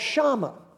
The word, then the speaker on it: Shama: It's spelled S-H-A-M-A, and it's called Shama. Can you say that?